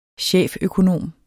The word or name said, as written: cheføkonom